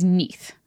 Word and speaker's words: Neith: So the only other like important goddess to note with Ra is Neith. It's very funny. It's spelled like Keith, but with an N instead of a K, oh, which I really like.